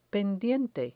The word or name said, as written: pendiente